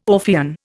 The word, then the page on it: Orphean: Indian